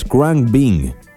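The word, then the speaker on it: Khruangbin: La pronunciación correcta es Crank Bing, sí, y es un trío de Houston, Texas, que son bastante conocidos.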